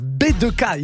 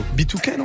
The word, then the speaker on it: B2K: Moins 1 pour la prononciation. B2K, il nous a fait à la française, DJ Géo. B2K, non On peut dire B2K ou pas on peut dire B2K, on va dire qu'on est des Américains.